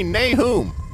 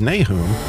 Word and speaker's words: Nahum: Nahum is the Hebrew pronunciation. Nahum is more Americanized. But when in America, Nahum it is.